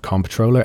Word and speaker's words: comptroller: Welcome back to FinTech Insider News, where we have Googled the definition of comptroller, and it is in fact a controller. – With a silent P. – With a silent P. – How silent is it though? Comptroller, it's an awkward word.